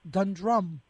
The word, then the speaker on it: Dundrum: I go to Dundrum. I'm not putting the accent on the right syllable. It's Dundrum. Is that right? – Yes.